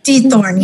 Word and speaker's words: Diethorn: Marne, did I say that correctly? – Diethorn, yes.